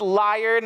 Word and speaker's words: lyre: It's pronounced lyre, not like Jim Carrey, not like a liar, liar, liar. It's like an instrument.